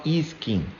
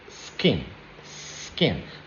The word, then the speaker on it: skin: So we say e-skin, but it's not e-skin, it's skin, skin.